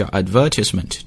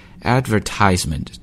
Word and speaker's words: advertisement: 那么刚才呢我读广告，我把它读成了叫 advertisement，这是英式英语，而美式英语呢读的是 advertisement， advertisement 重音呢落在这个 advertisement，落在这个 tie 上面 advertisement， but if you're speaking British accent it's advertisement，重音呢落在这个 ver 上面，之前我们在晨读当中也提过啊，在这里我们再复习一下。